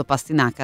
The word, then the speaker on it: pastinaca: È giusto pastinaca l'accento? Beh sì. – Ma sì, se no è pastinaca o pastinaca. – No, direi pastinaco. Esatto, una bella anche... piana, una bella parola piana, va bene così.